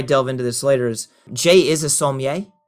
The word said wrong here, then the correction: sommelier: Did I say that correctly? – It's close enough. Certified sommelier. – Sommelier. Sorry, I dropped out that one syllable.